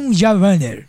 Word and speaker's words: Jean Valjean: Jean Valjean.